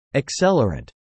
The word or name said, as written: accelerant